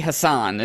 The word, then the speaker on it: Hassan: Someone brought up, and I could not agree more, is none of the national pundits were able to say her name correctly. She was Maggie Hassan.